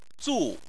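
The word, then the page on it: zu: tone 4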